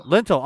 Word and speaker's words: lintel: And strike the intel. Lintel. I'm sorry. That's an L.